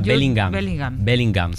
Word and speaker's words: Bellingham: Bellingham.